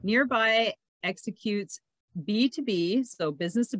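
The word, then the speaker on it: Nrby: It's nearby, so it's spelled NRBI, and I was You don't even want to hear what I thought it said.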